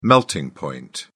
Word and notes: melting point: British English

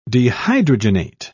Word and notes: dehydrogenate: British English

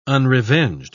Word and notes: unrevenged: British English

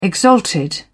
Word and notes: exalted: British English

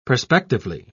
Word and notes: perspectively: British English

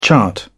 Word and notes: chart: British English

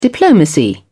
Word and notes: diplomacy: British English

